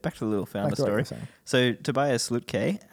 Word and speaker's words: Lütke: What's his last name? – Lütke. – Oh, is that how you say it? – Yeah.